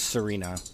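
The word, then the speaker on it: Tsareena: It's just Serena. – It's just. – Pretty sure the T is silent. – Serena. – Like Tsunami.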